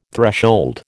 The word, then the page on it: threshold: American English